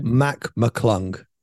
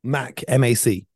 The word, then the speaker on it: Mac McClung: Talking about Matt McClung, is that how you pronounce his name? McClung? – Mac McClung. – Matt McClung. – Mac, M-A-C.